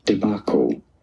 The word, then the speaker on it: debacle: So for reference, you have to know that this is a word of French origin. And in French, it is said as débâcle, débâcle. This is why in English it is to be pronounced debacle, debacle.